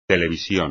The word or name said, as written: televisión